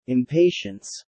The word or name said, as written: impatience